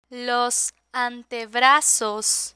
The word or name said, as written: los antebrazos